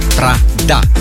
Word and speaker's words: Prada: Prada, da? Să, nu știu dacă pronunția s-a auzit corect, să nu vă ducă cu gândul la capitala Cehiei.